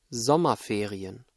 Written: Sommerferien

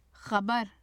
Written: xabar

